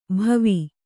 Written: ಭವಿ

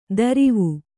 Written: ದರಿವು

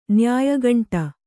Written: ನ್ಯಾಯಗಂಟ

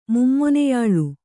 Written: ಮುಮ್ಮೊನೆಯಾಳು

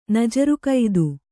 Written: ನಜರುಕೈದು

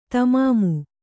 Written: ತಮಾಮು

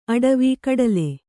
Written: ಅಟವಿ

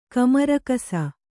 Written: ಕಮರಕಸ

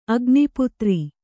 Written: ಅಗ್ನಿಪುತ್ರಿ

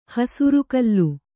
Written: ಹಸುರುಕಲ್ಲು

